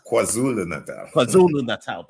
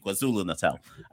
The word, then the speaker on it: KwaZulu-Natal: You were in, I always say it wrong, but let's just say Natal. But I always get the name wrong. – KwaZulu-Natal. – Thank you, KwaZulu-Natal.